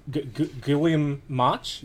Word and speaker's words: Guillaume March: James Tynion and I'm going to butcher this name. Guillaume March? – Yes.